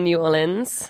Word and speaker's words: New Orleans: So Ryan, you live in New Orleans.